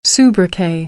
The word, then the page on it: sobriquet: American English; oʊ